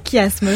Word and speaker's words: chiasme: C'est que ça se prononce bien chiasme.